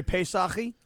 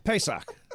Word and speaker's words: Pesach: Very Pesach-y. – Yep. – What? – You know? – That is how you say Passover in Pesach. – Hebrew.